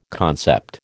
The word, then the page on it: concept: American English